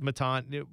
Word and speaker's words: Matan: I don't want to say, because John Zidar, who I love, my buddy, he was on Twitter saying, does everybody know it doesn't rhyme with Satan? It's it's not Hail Matan. It's Matan. Right.